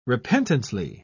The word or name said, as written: repentantly